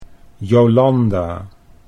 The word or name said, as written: Jolanda